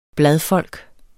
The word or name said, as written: bladfolk